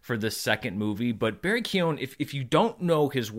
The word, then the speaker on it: Keoghan: Barry Keoghan, who is apparently our next Joker, which I'm glad that they were able to keep that under wraps.